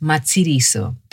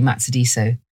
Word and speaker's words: Matsidiso: My name is Matsidiso. It's a South African name, and it really should be pronounced Matsidiso. But, well, I was raised in London, and this is my accent, so I say Matsidiso.